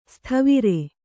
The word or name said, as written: ಸತ್ವರ